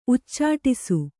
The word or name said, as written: ಉಚ್ಚಾಟಿಸು